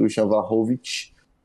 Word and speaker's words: Vlahovic: do Dusan Vlahovic. Mas... – Caralho! Meteu a pronúncia, olha viado.